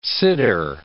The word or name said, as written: siddur